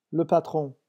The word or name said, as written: le patron